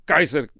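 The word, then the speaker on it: Caesar: Kaiser é a maneira, aliás, como se pronuncia César em latim, Kaiser, é assim que se pronunciava em latim o nome de César,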